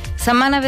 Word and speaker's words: setmana: La T s'assimila a la M i diem setmana, no pas setmana, setmanal, no pas setmanal.